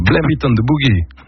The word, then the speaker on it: Blame It on the Boogie: Et les Jackson 5, Laurent Romeschko, Blame It on the Boogie.